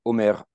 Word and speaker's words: Omer: Mentioning Clémentine Hunter and mentioning Plessy, Omer Adolphe Plessy, as I like to say his name, instead of Homer Plessy,